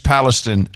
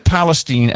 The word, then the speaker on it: Palestine: The people of East Palestine. And why is it Palestine and not Palestine, even though you spell it the same way? – That's the way they pronounce it there.